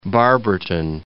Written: Barberton

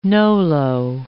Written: nolo

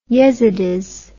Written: yezidis

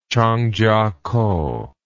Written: Changchiakou

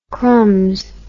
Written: crumbs

